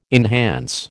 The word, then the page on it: enhance: American English